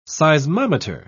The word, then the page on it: seismometer: British English